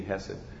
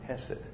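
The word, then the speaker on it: chesed: It's supposed to be pronounced Hesed, but I feel silly after a while saying that. So just give me Hesed.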